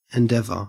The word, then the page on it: endeavour: Australian English